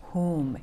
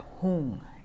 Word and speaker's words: Hum: Hum, H-U-M. The Tibetans pronounce it Hung, H-U-N-G. Hung has a force to it, an energy.